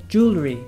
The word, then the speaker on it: jewelry: jewelry, jewelry, jewelry, jewelry, jewelry. – Jewelry. – Yeah, so it's got 3 syllables.